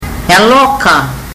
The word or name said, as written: yaloka